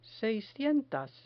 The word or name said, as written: seiscientas